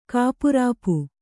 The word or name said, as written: ಕಾಪುರಾಪು